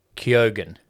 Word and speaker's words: Keoghan: In a Q&A with Geek Island, the actor explained that his surname is pronounced Keoghan.